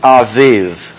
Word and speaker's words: Aviv: 13:4 says, on this day in the month Abib, and it is pronounced Aviv. In the month Aviv, sound the B like a V.